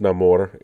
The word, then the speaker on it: Namor: Um, so it is pronounced Namor in the movie because he was named by his enemies like someone without love, no no amor, uh, for in Spanish.